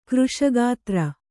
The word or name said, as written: ಕೃಶಗಾತ್ರ